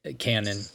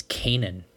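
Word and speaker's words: Canaan: I think Isaiah Cannon. I think it's Kanan. They were pronouncing it Kanan in the game last night.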